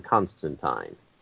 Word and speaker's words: Constantine: His name rhymes with turpentine. It's John Constantine.